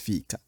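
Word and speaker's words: fika: Vuelvo a una de las palabras que yo más he usado aquí en el podcast, que es fica. Fica. Así como lo estoy pronunciando, creo yo, que significa es un, no sé, 11, es media 9, es un descanso para ir a comer panecillos y café. Uh-huh. Pero está fica. Y yo no sé si ustedes escuchan diferencia, pero el otro fica es un bolsillo.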